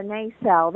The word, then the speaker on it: nacelle: I think you said nacelle, is that right? – Yeah, the nacelle. – How do you spell that? – That's N-A-C-E-L-L-E.